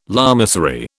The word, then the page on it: lamasery: British English